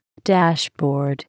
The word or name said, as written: dashboard